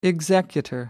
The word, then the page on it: executor: American English